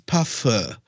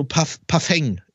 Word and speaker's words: Parfum: Und zwar nichts Ekelhaftes oder so, nichts so hier Pipi oder Pipi Kaka, sondern eine Overdose Parfum. Ja, so Parfeng. Wie spricht man es richtig aus? Parfum. Parfum. Ey, so Menschen, die einfach nicht raffen, wann genug ist mit diesem Parfum.